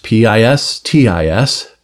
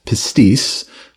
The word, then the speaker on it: pistis: Uh, I'll spell it first P I S T I S, pistis, or pistis, or pisteuo, and it's the word faith.